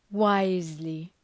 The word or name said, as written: wisely